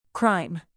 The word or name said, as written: crime